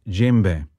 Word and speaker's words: djembe: So African Suite, the movement we played was djembe. Djembe being a name for a drum you find in parts of West Africa. And that is spelled D-J-E-M for Mozart, B-E. D-J-E-M-B-E, djembe, from the African Suite by Andrew York.